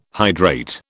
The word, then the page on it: hydrate: British English